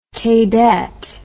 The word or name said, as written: kaydet